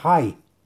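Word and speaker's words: Hai (חי): Está recomendada por la página de Facebook Kabbalah y Torah en expansión y trae un nombre sagrado para meditar que son las letras Het Yud y se pronuncia Hai.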